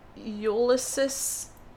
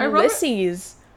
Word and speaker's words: Ulysses: People like Ulysses... Is that how you pronounce it? – No! – How do you pronounce it? – Ulysses.